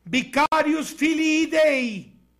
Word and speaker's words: Vicarius Filii Dei: en latín se pronuncia Vicarius Filii Dei,